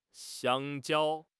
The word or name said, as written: xiang1 jiao1